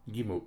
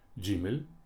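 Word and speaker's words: Гимель (ג): Гимель мы не знаем, как, какие два произношения она имела, сегодня она имеет одно произношение, но, скажем, у йеменских евреев мы встречаем более позднее Гимель и Джимель, Г, Дж.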